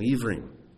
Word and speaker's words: ivrim: You replace the vav up here with the bet, it becomes Hebrews, but it's pronounced exactly the same, ivrim.